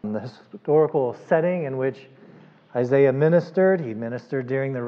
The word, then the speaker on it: Isaiah: We are continuing our study here this evening in the book of Isaiah, and I discovered the pronunciation of Isaiah. I was listening to a British theologian, and that's the British pronunciation. But Isaiah, we're here in Texas.